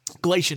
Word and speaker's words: Glacier: Of course, the attack occurred on the same night, August 13, 1967, also by a wild brown bear at Glacial National Park. Glacier National Park.